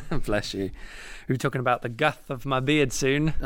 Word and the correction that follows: girth: Aye, the girth. – What a word. – You forced me to actually say it correctly.